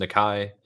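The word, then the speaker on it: Nikkei: It is pronounced Nakai, and it is the stock market in Japan.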